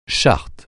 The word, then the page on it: charte: français